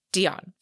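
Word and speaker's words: d'Éon: I would say d'Eon. – We're going to say d'Eon. d'Eon. Mm-hmm. Okay. It's d'Eon. So d'Eon.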